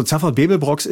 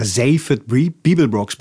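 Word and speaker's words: Zaphod Beeblebrox: Ja, also Zaffert Bebelbrox ist die, oder Zaffert Bebelbrox müsste man eigentlich sagen. Wir haben es dann halt Zaffert Bebelbrox genannt, also einfach deutsch ausgesprochen,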